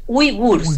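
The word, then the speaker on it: uigures: que es la minoría de los uigurs, como la José, la que habla chino acá, no sé si lo dije bien. – Súper bien.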